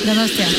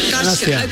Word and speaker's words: Donostia: Donostia. – Donostia. – Donostia.